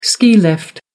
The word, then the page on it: ski lift: American English